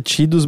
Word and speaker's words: Tidus: A pronúncia correta é Tidus, mas eu me recuso, é Tidus.